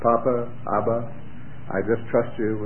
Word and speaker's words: papa: Papa. – Papa. Good. And the French is papa, accent on the last syllable.